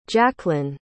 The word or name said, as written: jacklyn